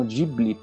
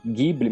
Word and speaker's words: Ghibli: Alguns pronunciam Ghibli, parece que os japoneses chamam de Ghibli. A gente acaba usando a, a pronúncia italiana né, de Ghibli, mas é uma palavra com origem árabe, na verdade, enfim.